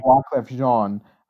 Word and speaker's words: Wyclef Jean: I think it's Wyclef Jean. – Jean, sorry, yeah. Wyclef Jean.